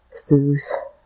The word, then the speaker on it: Seuss: So Seuss, like that Seuss rhyme with Mother Goose, so he adopted the pronunciation.